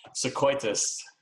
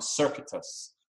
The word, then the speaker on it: Circuitous: Sequoitus. – it's pronounced one way than it's actually sung. It's sung Circuitous.